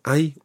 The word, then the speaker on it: Hay: Hay is spelt H-A-Y. Hay. – Hay. – Hay. – Hay. – When you see that word, it's quite tricky to remember that you don't pronounce the H.